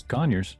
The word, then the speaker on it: Conyers: Did I say that right? Conyers, Georgia? – Yeah. Oh, it's Conyers. – Yeah, it's Conyers.